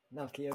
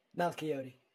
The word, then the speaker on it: Malchiodi: Did I say your last name correctly? – Yeah, close enough. Malchiodi. – Close enough. How do you, okay, you say it. How do you say it? – Malchiodi.